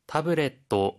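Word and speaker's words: 태블릿 (タブレット): 태블릿, 태블릿 태블릿입니다. – 프린터인데요. – 혹시 발음 팁 있을까요? – 하이, 파소콘이라는 단어는 원래 파소널 컴퓨터의 줄임말이고요. 태블릿의 끝에 발음은 또가 되는 점 주의하시면 좋겠습니다.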